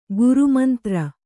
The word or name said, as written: ಗ್ರಾಮಾಂತರ